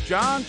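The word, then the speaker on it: Jon: John Chuckery in here. I spell it the right way though. It's J-O-N, not J-O-H-N.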